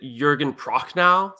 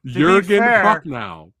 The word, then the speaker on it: Jürgen Prochnow: Wait, is it Jürgen Prochnow? – See, this is why I have here. – Well, Jürgen Prochnow.